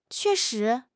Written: que4 shi2

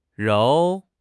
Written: 揉

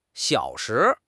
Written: xiao3 shi2

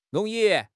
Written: rong2 yi4

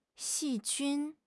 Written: 细菌

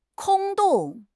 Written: 空洞

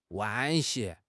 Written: 惋惜

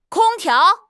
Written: kong1 tiao2

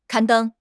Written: 刊登

